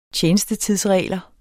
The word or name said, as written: tjenestetidsregler